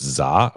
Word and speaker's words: SZA: I, I it's Za.